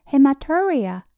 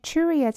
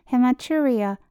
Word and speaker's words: hematuria: はい、最初はね、尿量、 urine output、 urine output 無尿 anuria anuria 防尿 oliguria oliguria 多尿 polyuria polyuria 排尿障害 dysuria dysuria 血尿 hematuria hematuria hematuria の発音なんですけれど、アメリカだと hematuria ね、 churia じゃなくて hematuria だそうなんですけれどね、なんかオーストラリアでは hematuria と発音してますね。